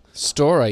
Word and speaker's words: Storer: Storer, yeah.